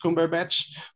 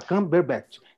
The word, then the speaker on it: Cumberbatch: Melhor ator, principal, Andrew Garfield por Tic Tic Boom, Benedict Cumberbatch por Ataque dos Cães, Denzel por Tragédia de Macbeth, Peter Dinklage por Cyrano ou Will Smith por King Richard.